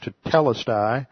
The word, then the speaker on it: tetelestai: It was only after that time that Jesus said that it was finished, tetelestai. T-E-T-E, notice the accents on the second syllable, so it's tetelestai. T-E-T-E-L-E-S-T-A-I.